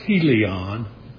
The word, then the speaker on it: Chilion: Chilion." I looked, there's a fella on the internet that's from that part of the world, and he says that should be pronounced Helion. Doesn't much matter.